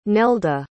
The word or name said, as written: nelda